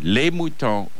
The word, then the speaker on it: les moutons: Mouton is mouton. Les moutons are mouton.